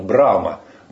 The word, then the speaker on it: Брахма: Он же, вот есть троица такое, Шива, Вишну и Брахма. Брахма пишется, но хэ, так говорится, что его не слышно. Брама.